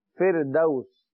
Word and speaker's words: فردوس: جنت الفردوسی, اردو میں ہم کہتے ہیں فردوس, جنت الفردوس, لیکن عربی میں اس کا صحیح تلفظ ہے فردوس, فردوس, دال واو زبر دو حروف لین یاد کیجئے.